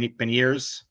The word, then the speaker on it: Beniers: This is like it's going to take so much more too than some for someone to catch him than it was for Beignet or ben, Beniers.